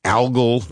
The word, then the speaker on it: algal: They're even developing algal jet fuel. And by the way, that's the right pronunciation, algal. It's not algal, algal, even though it's out of algae.